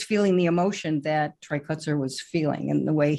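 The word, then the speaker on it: Kotsur: I'm wondering if he maybe knows him or has known Mr. Kushner for many, many years. I hope I'm not butchering his last name, by the way. – Right, Kutzer. – Kutzer is his Kutzer.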